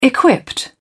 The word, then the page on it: equipped: British English